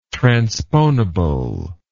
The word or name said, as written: transponible